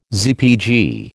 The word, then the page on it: ZPG: American English